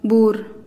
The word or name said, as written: buur